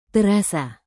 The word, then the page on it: terasa: indonesian